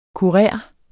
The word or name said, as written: kurer